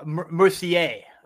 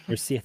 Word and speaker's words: Mercier: Oh, Mercier. – Mercier, thank you.